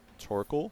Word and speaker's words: Torkoal: We have Torkoal. So I think that's how you say it, right? – Yeah, sure, got it. It's a tortoise with the charcoal in its lovely shell. Torkoal.